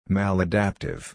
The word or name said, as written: maladaptive